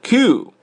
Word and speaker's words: queue: who jumped the queue，一个单词 q 啊，就是 q 的发音呢， q 长一点啊， q 它表示队伍，做动词来用就是排队。